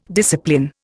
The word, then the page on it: discipline: Indian